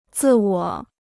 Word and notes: 自我: zì wǒ

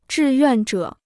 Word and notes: 志愿者: zhì yuàn zhě

